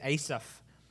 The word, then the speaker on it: Asaph: Hebrew, it's pronounced a little differently, but for our American ears, Asaph.